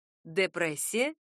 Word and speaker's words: депрессия: Звук «д» первый в этом слове может быть как мягким, так и твердым. Депрессия — депрессия.